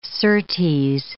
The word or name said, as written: certes